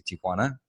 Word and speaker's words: Tijuana: You know what I love most about that entire intro is that you pronounce Tijuana the right way. – Nice. – Because most of the times, even when I say it to Americans, I have to say Tijuana, which is like okay. Not the right way to say it. It's actually Tijuana. And you pronounced it correctly.